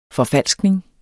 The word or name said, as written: forfalskning